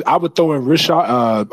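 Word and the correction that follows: Risacher: I don't know if I'm saying his name correctly. Rishire. – It's Reese Shea.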